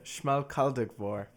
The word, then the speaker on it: Schmalkaldic War: It was called the Schmalkaldic War? – Yeah, let me see. It's the Schmalkaldic War.